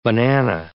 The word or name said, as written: banana